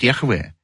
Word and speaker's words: Яхве: Имя Божие по-еврейски звучит Яхве.